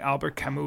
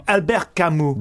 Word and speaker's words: Albert Camus: It was almost like what something Albert Camus would write, you know, kind of like a stranger. – All right. Yeah, that's maybe Albert, a little lofty. – I pronounced it wrong. Albert Camus.